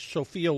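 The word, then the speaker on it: Sephia: Even though it's pronounced, even though it's spelled S-E-P-H-I-A, I believe it was pronounced like Sophia Loren.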